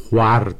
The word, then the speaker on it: خور: هر هوروش که بر مه و خور حسن میفروخت، یا مه و خور، گفتم اینا خور تلفظ میشده و این واو معدوله چون تلفظش سخت بوده در خواهر، خور، خواستن، یواش یواش اینا دیگه حذف شده.